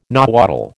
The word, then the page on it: Nahuatl: American English